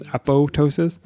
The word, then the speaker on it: apoptosis: I'd like to note that the second P is silent. No popping here. So Justin's referring to the apoptosis versus apoptosis. Apoptosis.